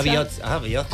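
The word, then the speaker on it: bihotza: Con TZ. Ojo, TZ. Biotza. Que no tenemos TH, tenemos TS, TZ y TX. La TX se pronuncia como ch. La TZ como y la TS como TS, o sea, como una CH normal.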